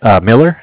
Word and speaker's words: Miller: My name is Zach, spelled Z-A-C-H. – Z-A-C-H. Your last name, Zach? – Miller, M-I-L-L-E-R. – M-I-L-L-E-R.